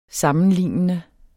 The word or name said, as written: sammenlignende